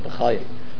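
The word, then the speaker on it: Bachya: and then either some people pronounce it Bachi and others pronounce it Bachai,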